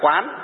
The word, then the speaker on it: quán: Khi đọc là quán tức là phải dùng bằng gì ạ? Dùng bằng tâm. Khi đọc là quan tức là dùng bằng gì? Bằng con mắt mà nhìn. Cho nên cái từ quan này hay từ quán này có thể đọc thành hai đều được.